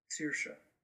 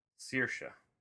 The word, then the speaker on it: Saoirse: Saoirse. – Saoirse.